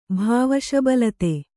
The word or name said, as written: ಭಾವಶಬಲತೆ